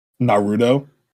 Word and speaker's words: Naruto: You say his name so wrong sometimes. Now, how do you say it? Say it. – Naruto. – You're saying it right now. How did you say it before? – Naruto.